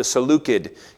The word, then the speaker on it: Seleucid: You actually would have pronounced it the Seleucid kingdom, but that sounds weird because everybody else says it the other way now.